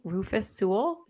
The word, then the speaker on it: Rufus Sewell: But while we're on the topic, there was a show with, I don't even know if I say his name right, Rufus Sewell.